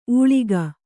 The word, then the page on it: ಉಳಿಗ: uḷiga